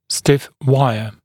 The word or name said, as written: stiff wire